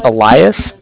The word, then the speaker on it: Elias: So my name is Elias, E-L-I-A-S. And I'm calling with the Bank of Cardiff. Cardiff is spelled C-A-R-D-I-F-F, as in Frank Frank.